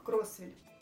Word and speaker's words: Кроссвилль: Но ударение мы ставим именно на первый слог Кроссвилль.